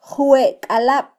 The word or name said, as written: jwekalab